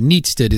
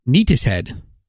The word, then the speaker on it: Neatishead: By the way, Neatsted is apparently the traditional pronunciation of Neatsted, but the RAF, however, pronounces it Neatishead.